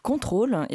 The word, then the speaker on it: CNTRL: Julie a rencontré le collectif Contrôle, et ça s'écrit CNTRL, ça, ça s'entend pas à la radio, qui a fait une soirée queriote vendredi à la Java et qui fait une soirée euh, pastorale au club le 5 juillet.